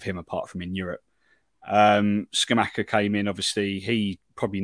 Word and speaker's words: Scamacca: Because I know you've got the Italian striker up front, which name who I'm probably not going to try and pronounce, Scamacca? – Scamacca, yeah.